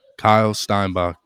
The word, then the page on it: Kyle Steinbach: male